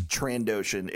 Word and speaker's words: Trandoshan: I always thought there was a soft S after the N. – It's Trandoshan.